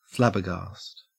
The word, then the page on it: flabbergast: Australian English